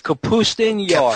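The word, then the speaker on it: Kapustin Yar: called Capustin. Have I got that right? – It's Capustin Yar. – Capustin Yar. Okay. Much better pronunciation than what I just destroyed.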